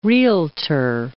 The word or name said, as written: Realtor